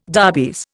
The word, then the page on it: darbies: Indian